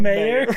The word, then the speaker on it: Meyer: Schmelga but it's spelled different. It's spelled like M-E-Y-E-R. – Yeah, like John Mayer or something like that.